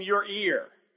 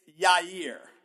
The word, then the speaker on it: Yair: Told me I'm throwing an extra R in there. – You are. You're saying your ear. – Yes. – It's Yair. – I was told to say your ear. Yair.